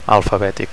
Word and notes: alfabetic: Catalan

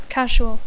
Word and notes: casual: English

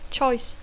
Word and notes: choice: English

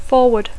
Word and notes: forward: English

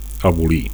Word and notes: abolir: Catalan

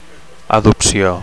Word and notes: adopcio: Catalan

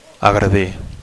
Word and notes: agredir: Catalan

